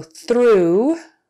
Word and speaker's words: through: So through, T-H-R-O-U-G-H, through. I will go through the hoop. Throughout means everywhere, throughout. We can smell the popcorn throughout the theater, throughout. So in those 2 cases, O-U-G-H is pronounced ooh.